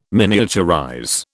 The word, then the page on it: miniaturize: British English